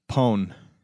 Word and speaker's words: pwned: Pone.